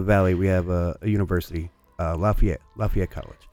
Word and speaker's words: Lafayette: But I went down to Tennessee when I was young, and there's a town spelled the same way. Looks just like Lafayette. But you say Lafayette, these people get angry at you. Like, it's not Lafayette. It's Lafayette.